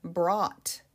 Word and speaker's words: brought: Brought. Brought. This is B-R-O-U-G-H-T. That ought sound at the end can be difficult.